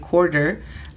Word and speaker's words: quarter: Y la pronunciación fonética es C-O-R-D-R, quarter. Cuando yo era pequeña y trataba de recordar cuánto valía cada moneda, al menos con el quarter, se me hacía muy fácil el imaginar que el dólar se dividía en 4 partes.